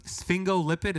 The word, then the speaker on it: sphingolipid: So sphingolipid, is that how you say it? – Mm-hmm.